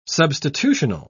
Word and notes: substitutional: British English

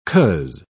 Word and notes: coz: British English

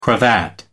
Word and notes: cravat: American English

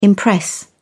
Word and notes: impress: British English

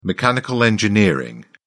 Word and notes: mechanical engineering: British English

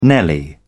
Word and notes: nelly: British English

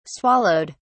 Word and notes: swallowed: British English

